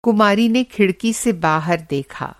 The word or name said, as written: कुमारी